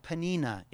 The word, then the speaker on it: Peninnah: Elkanah had two wives, Hannah and Peninnah is her name.